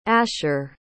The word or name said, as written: asher